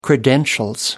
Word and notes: credentials: British English

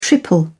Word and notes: triple: British English

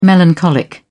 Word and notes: melancholic: British English

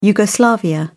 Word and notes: yugoslavia: British English

